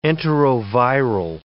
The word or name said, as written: enteroviral